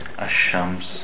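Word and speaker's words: Ash-shams (الشمس): Sheen is one of the letters you don't pronounce the lam before it. Ash-shams, ash-shaytan, for example.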